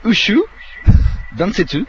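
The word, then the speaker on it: Ushu Densetsu (宇宙伝説): Je vais vous le prononcer. C'est Ushu Densetsu.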